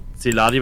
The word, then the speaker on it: Celary: Ähm, ja, also ich bin Dominik Celari, also Celari wird mein Nachname aus, ausgesprochen.